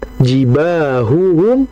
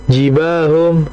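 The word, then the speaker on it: jibahuhum (جباههم): Maka jangan kemudian kita bacanya jibahum, jibahum, jibahum, jibahum, jibahum. Jangan.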